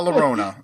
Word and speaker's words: Llorona: La Rona, La Llorona, The Curse of La Llorona.